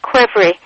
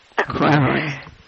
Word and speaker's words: qvevri: Quivery. – Quivery. – It's in the book it's spelled Q-V-E-V-R-I. – Yeah. – But just think of it as K-W-E-R-V-R-I. Then you get Quivery. – Quivery.